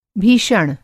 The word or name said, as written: भीषण